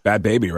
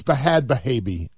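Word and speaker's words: Bhad Bhabie: Danielle became a rapper known as Bad Baby, right? – Right. Is that what it's supposed to be? – BHAD. – Oh, you're a rapper, so you got to spell it wrong. – Right. So it's Bahad Bahabi.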